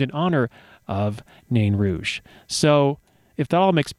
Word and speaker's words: Nain Rouge: Nain Rouge is the pronunciation. I've had a hard time with that.